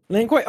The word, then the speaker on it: Lin Kuei: Lin Kuei.